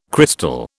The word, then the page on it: crystal: British English